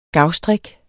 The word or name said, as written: gavstrik